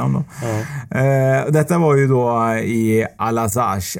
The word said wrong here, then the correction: Alsace: eller Alazac. Jag är så dålig på att uttala franska namn alltså. Alsace, men det är nu i alla fall i moderna Frankrike det ligger i.